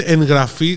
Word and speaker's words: εγγραφή: Η εγγραφή προφέρεται εγγραφή, δεν υπάρχει γκου γου γου, όπω γράφεται.